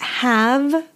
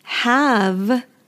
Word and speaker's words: have: So we're going to talk about have versus has. Really quickly, let's talk about pronunciation. Have, we have a strong V ending. Have, have.